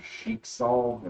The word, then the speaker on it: Schicksal: Don't try to pronounce it as best we can, but from what it looks like, it's Schicksal, and that's S-C-H-I-C-K-S-A-L. – Schicksal.